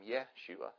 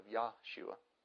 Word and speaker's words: Yeshua: There's a couple of people that I've talked to out of state, not here, that think I'm going to hell because I pronounce the Messiah's name Yeshua instead of Yahshua.